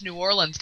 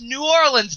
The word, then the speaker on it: Orleans: No, it's New Orleans, damn it. – There's no I. It's not ins.